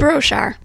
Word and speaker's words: Broshar: Broshar.